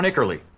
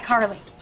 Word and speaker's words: iCarly: Either that, or we'll have to shut down Ickerly. – It's pronounced iCarly. – Ah, sorry, iCarly. That's my hat.